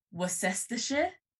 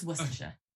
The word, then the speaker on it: Worcestershire: It should technically, phonetically be like Worcestershire, but it's just Worcestershire.